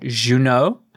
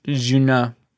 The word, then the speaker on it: Juneaux: And then I also recommend Better Off by an artist called Juno. And it's spelt J-U-N-E-A-U-X. – Wow. – Juno.